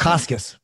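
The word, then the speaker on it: Bukauskas: And Bukakis being the how do I pronounce it? Bukakis.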